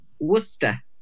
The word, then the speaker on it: Worcester: Worcester. I mean, it's spelled Worcestershire, but it's just Worcester.